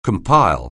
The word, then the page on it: compile: anglais